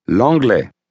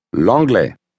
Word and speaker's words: L'anglais: L'anglais. L'anglais. – How do you say English? – L'anglais. – Now say the same word, leaving off the L sound at the beginning.